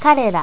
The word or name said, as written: karera